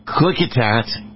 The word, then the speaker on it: Klickitat: He is the sheriff of Klickitat County. I think I said that correctly. Sheriff, correct me if I messed that up. Did I say that right? – Yes, you did.